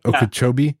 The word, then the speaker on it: Okeechobee: A Florida man evades arrest by diving into Lake Okie, Okeechobee, Oke, wait, how do you say it? Okeechobee, yeah.